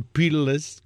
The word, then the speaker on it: Peerless: cerca pirles, sin peladuras. Ah, porque puso perle. Y el productor pone peerless, como se escribe, peerless, sin peladura.